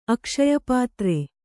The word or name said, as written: ಅಕ್ಷಯಪಾತ್ರೆ